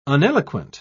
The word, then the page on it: uneloquent: British English